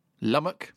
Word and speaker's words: Lumoch: I've got one here from Lummock at Lummock, L-U-M-O-C-H. Have I said that right? Lummock. Yeah. I think so.